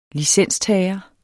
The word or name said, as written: licenstager